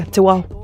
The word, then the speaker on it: Te Wau: My family name's Te Wau. – How do you pronounce the W word? Wow. – Yeah, Te Wau.